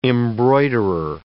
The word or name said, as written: embroiderer